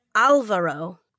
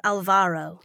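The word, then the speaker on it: Álvaro: I was I was looking him up while I was waiting before, and I think his name is pronounced Alvaro, not Alvaro.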